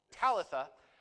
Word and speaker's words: Talitha: Her name is Talitha. Talitha. You said it. Emphasis on the wrong syllable. Talitha.